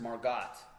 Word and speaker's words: Margot: Her name is pronounced Margot. – Mm. – Kind of like Gail... – Good dough.